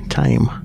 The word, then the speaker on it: Thame: And saying that, there's a local river called the River Tame, and that is exactly the same, T-H-A-M-E, Tame.